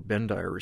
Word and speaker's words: Bendire: And so you had people like Charles Bender, or Bendire as his name is often pronounced,